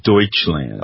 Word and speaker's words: Deutschland: No, it's Deutschland. – Oh, you learned how to say it right.